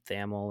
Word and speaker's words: Thamel: Um, I, I'm probably going to mispronounce his last name, Pete Thamel or Tamil. – It's um, Thamel.